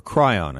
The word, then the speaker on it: chyron: And throughout the speech, they had a message at the bottom of the screen, what's called a chyron, or cryon, I should say. Sorry, I mispronounce it all the time. It's what's called a cryon.